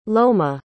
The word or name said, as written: loma